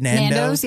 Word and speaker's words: Nando's: She also says she eats Nando's, the chicken place. Is that how you say it? – Nando's, yeah.